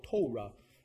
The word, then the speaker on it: Torah: I like the way they say that long O, Torah.